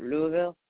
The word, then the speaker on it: Louisville: And they don't say Louisville, they go Louisville. Louisville. It's like they skip like Mullins, they skip a syllable because, you know, that's a lot of work.